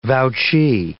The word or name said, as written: vouchee